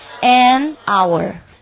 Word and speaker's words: hour: 但是由于是 hour，所以要读成什么 hour？ an hour。